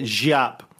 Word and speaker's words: Giap: So JAP, G-I-A-P, for people if they think I'm saying J-A-P. No, right. He's not that kind of JAP. JAP. I think it's pronounced JAP.